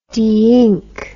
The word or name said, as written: deink